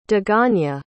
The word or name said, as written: daganya